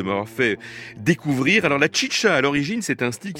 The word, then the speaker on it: Xixa: Alors tout de suite, autre chose, mais enfin on reste dans la même région, le désert de l'Arizona et Tucson, c'est Chicha, là ça s'écrit XIXA,